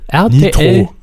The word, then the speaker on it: RTL Nitro: ähm, ja, auf jeden Fall am Vorabend bei RTL Nitro, so wird der Sender übrigens laut Trailer ausgesprochen, weil viele auch äh, gefragt haben, wird der jetzt Nitro ausgesprochen oder RTL Nitro oder was weiß ich. Nein, RTL, RTL Nitro. – Nitro.